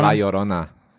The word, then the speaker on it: La Llorona: Is that how you say it? – Llorona. – Llorona. – La Llorona. – La Llorona. – Llorona. – Llorona. – Yeah. You have to roll the R too much.